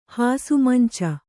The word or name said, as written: ಹಾಸುಮಂಚ